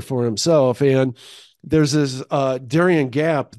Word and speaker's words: Darien Gap: The Darien Gap.